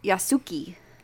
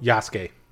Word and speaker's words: Yasuke: The true story of Yasuki. – Nope. – Yasuke. – No. – I did the same thing. Captain's right. – Wait, is it Japanese? – Yes. – Then you pronounce all the letters. – Yasuke. Not always. – I promise you, it's Yasuke.